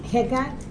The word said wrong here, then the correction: Hegai: Is that how you say it? – Hegai, who is what? – He's the prince of the eunuch, okay?